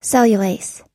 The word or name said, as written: cellulase